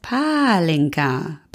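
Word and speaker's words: pálinka: Es heißt Palinka. Betont wird im Ungarischen immer die erste Silbe.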